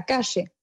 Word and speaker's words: calle: Ben en fait, le double L et le Y se prononcent che, donc la calle, la rue, par exemple, c'est la cache.